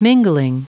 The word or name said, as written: mingling